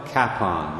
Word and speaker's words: Kapaun: Father Emil Kapaun, that's spelled K-A-P-A-U-N,